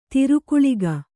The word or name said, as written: ತಿರುಕುಳಿಗ